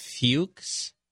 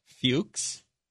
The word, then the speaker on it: Fuchs: Her name is Virginia Fuchs. I'll pronounce it that way. – That's not how you pronounce it. – Fuchs. – It's a hard sound at the end.